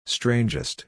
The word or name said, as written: strangest